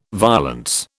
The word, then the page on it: violence: British English